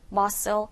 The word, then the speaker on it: muscle: 여기서 발음을 주의해야 될 게 스펠링이 S도 보이고 C도 보이지만 이 C 발음이 K 사운드가 나지 않아서요. muscle, 이 S 사운드 쓰면 난다라는 것만 기억하시면 돼요.